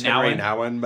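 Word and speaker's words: Henri Nouwen: Dress as Henri Nguyen. Is that how you say it? – I think it's just Henry Nouwen, but maybe.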